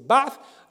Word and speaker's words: Ba'ath: En arabe, il faut prononcer Ba'ath. Je sais qu'en français, on l'écrit Basse. mais c'est une. Parce que certains orientaux, d'ailleurs, euh, prononcent. Euh, le, S à la place du, TH, mais c'est pas bon, c'est pas une question importante.